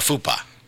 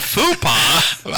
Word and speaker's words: faux pas: A faux pas. – Faux pas. – Oh, here's another word we can faux pas? Where did you get to faux pas? Not fupa. – I've always pronounced it fupa.